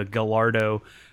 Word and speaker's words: Gallardo: So to all my, country bumpkin kinfolk who have always pronounced it as the Gallardo, and the Murcielago. – Murcielago. – Well, that's not how they pronounce it, but they did get Gallardo correct, so congratulations.